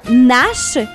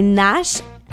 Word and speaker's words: Gnash: Ça s'appelle Nash, mais ça s'écrit G-N-A-S-H. Okay. Donc, lui-même le dit, on ne prononce pas le G. Donc, c'est Nash, G-N-A-S-H.